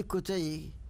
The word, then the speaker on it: كتي: هذه كتي هكذا تنطق، كانت اضيفت اليها النسبه البجائيه البجاويه.